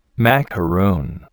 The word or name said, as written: macaroon